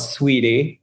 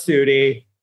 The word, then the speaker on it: Suitey: But in 2013, we were called Sweetie. And it's spelled S-U-I-T-E-Y. – I like that name, man. – What was it? It was kind of cute. It was a branding nightmare. Everybody called us Sooty.